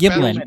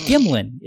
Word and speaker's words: Gimlin: You said Patterson-Gimley. – Oh. – Gimley happens. – Oh, I'm sorry. Oh, yes. Okay. Am I saying that wrong? – It was Gimlin. It's Patterson-Gimlin. – Gilman. – Sorry. Yes. No, it's Gimlin. It's G-I-M-L-I-N.